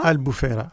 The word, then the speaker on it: Albufeira: Albufeira. – Albufeira. – Albufeira. – Et puis ses environs. – Oui. Quand je parlais de rares exceptions sur les effets moins positifs du tourisme, je pensais justement à Albufeira,